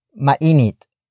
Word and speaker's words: mainit: Mainit. Ngayon. Sa. Labas. Ano. Mainit. Ngayon. Sa. Labas. Ano. The first word is mainit.